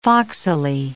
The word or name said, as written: foxily